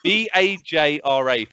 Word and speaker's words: Vajra: Vajra, thank you very much. – Oh, no, Vajra. – Okay. Sorry. That's how it's pronounced.